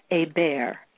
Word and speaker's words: Hebert: And my name, my pronunciation of my name is the French pronunciation. It's Michelle Hebert, H-E-B-E-R-T.